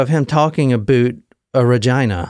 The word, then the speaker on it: Regina: The next stop was Regina, and that is how you say it, not Regina. It's Regina.